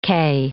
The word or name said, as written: kay